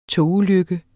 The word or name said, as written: togulykke